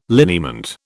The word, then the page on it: liniment: British English